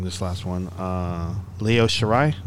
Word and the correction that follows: Io Shirai: did I say it right? – Io Shirai, yes.